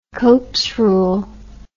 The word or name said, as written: copes rule